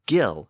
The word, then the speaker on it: gill: Another Norse word with a hard G is the word gill, as in gills of a fish. And girth also shows Norse influence. The word gear, G-E-A-R, is another Norse word.